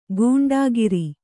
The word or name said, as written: ಗೂಂಡಾಗಿರಿ